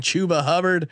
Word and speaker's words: Chuba Hubbard: You're welcome, America, for correctly pronouncing Chuba Hubbard.